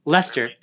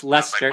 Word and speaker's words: Leicester: Leicester. Yeah, it's spelled like Leicester, but it's pronounced Leicester.